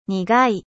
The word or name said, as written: 苦い